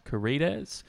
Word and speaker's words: Carides: A 1990 Australian black comedy / romance starring Sam Neill and Zoe Carides was Death in Where. Have I said that right? C-A-R-I-D-E-S. Carides.